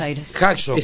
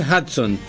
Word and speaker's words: Hudson: Hudson. – Se pronuncia Hudson. – Yo lo pronuncio Hudson. – Yo también Hudson. – Mucha gente lo llama Hudson.